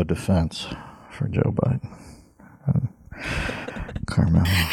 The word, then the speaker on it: Kamala: I found out you pronounce it Carmelo.